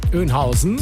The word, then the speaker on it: Oeynhausen: Wie würdest du es denn aussprechen? – Was denn bitte? Kreuz? – Kreuz Bad Oeynhausen. Auch so? – Oeynhausen. – Ja, ja, genau.